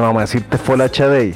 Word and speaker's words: Folashade: Su nombre es Ellen Folashade Adu, que así se pronuncia en eso, en ese idioma, Folashadei.